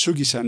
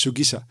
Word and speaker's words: Shugisha: Ah, die, meine Betonung war ein bisschen falsch. Es ist Shugisha, nicht Shugisha. Das I kriegt keine extra Betonung.